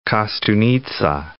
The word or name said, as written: Kostunica